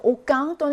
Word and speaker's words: aucun: 그래서 옥강 또는 여성으로 쓰이면 옥퀸으로 발음이 조금 달라지죠.